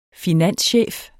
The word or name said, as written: finanschef